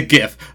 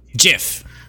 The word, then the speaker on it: GIF: Yeah, like, he just invented the GIF. Or, as he would pronounce it, the GIF. – GIF, yes. – I just learned today that the inventor of the GIF really thought it should be pronounced GIF.